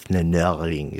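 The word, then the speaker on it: flâneur: I'm completely bastardizing the pronunciation. It's something more like flaneuring. It's a French word.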